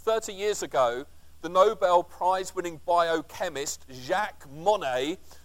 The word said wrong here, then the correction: Monod: or Monod, should I say, sorry, my French pronunciation is slightly askew.